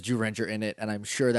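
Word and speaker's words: Zyuranger: We're saying Jurenja, even though it's spelled like Z-Ranger. – Right. – Because that's how it's pronounced.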